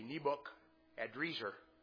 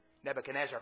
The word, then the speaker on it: Nebuchadrezzar: If you work through that phonetically, it's actually Nebuchadrezzar, which is a little better, different than Nebuchadnezzar, as we usually say it.